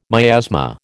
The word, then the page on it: miasma: American English